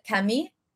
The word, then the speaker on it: Camille: Is how French people, like her name is spelled Camille, but French people pronounce it Camille.